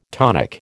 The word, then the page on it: tonic: American English